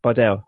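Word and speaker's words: Bardel: And it's also the animation, I think it's produced by, is it Bardem? Is that how you say it? They make Rick and Morty, I think. – Bardell, yeah.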